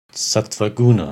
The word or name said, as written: Satva Guna